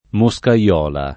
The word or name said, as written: moscaiola